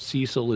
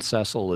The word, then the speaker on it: Cecil: I was gonna point out Cecil is the more American pronunciation and Cecil is the more British and perhaps Canadian pronunciation.